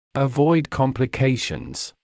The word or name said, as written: avoid complications